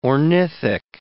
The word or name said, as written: ornithic